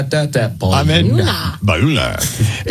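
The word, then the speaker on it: Bayona: And what beware, Bayuna. Is that how you say it? – Yep. Bayuna. – You got it.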